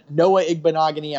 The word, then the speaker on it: Noah Igbinoghene: Noah Igbinoghene.